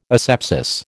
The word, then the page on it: asepsis: American English